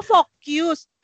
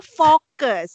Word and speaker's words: fokus: Oh, focus. Bukan, ini cara bacanya fokus.